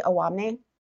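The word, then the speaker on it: Owamni: Awamni. Am I pronouncing that correctly? Awamni? – That is correct, yes.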